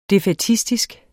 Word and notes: defaitistisk: [ defεˈtisdisg ]; [ defɑj- ]

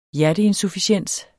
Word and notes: hjerteinsufficiens: dansk

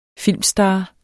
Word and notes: filmstar: -ˌsdɑː; -ˌsdɑˀ

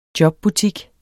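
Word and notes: jobbutik: Danish

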